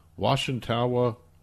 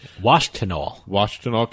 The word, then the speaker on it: Washtenaw: Jeffrey Phillips, age 62, of Washtenaw. I'm totally mispronouncing that. I can't speak. – Washtenaw County.